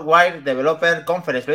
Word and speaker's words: Worldwide Developer Conference: la World la Worldwide Developer Conference.